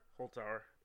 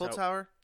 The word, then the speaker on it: Holzhauer: James, I don't know how to say his name. Holzhauer? – Holzhauer. – That's Holzhauer. – That's what I would go with.